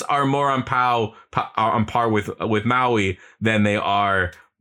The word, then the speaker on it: Maui: It is the best way, all right, to show the two how completely crazy the heroes is when oh my god, Maui? I said that right? Maui. Maui.